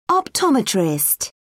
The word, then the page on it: optometrist: British English